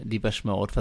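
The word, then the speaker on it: Depeche Mode: Das war Blasphemous Rumors von Deepesh Mode, was ich diesmal richtig ausgesprochen habe, wie mir mal meine beiden Mitstreiter äh, gerade bestätigt haben.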